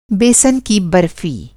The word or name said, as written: बेसन